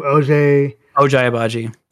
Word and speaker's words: Ochai Agbaji: Ochai Agbaji.